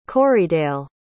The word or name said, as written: corriedale